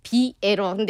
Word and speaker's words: 비에러: 국악의 어법은 그대로 담고 있는 그런 음악을 좀 해보고 싶어서 에러를 좀 넣어서 이제 비에러 근데 이제 비에러를 읽으면 삐에로라는 발음처럼 되는 네.